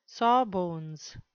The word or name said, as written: sawbones